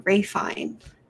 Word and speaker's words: Ralph Fiennes: It's Ray Fine because he's not American for whatever reason, even though his name is spelled like Ralph Fiennes.